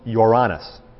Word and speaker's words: Uranus: Strictly speaking, it's pronounced Uranus, is the pronunciation.